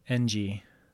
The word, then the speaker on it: Ng: I-N-G? – N-G. But it's pronounced Ng.